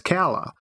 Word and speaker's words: Calla: And at the beginning of the book, the narrator says Calla. And I was like, mm-hmm. Oh, so I must be right.